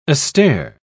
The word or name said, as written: astir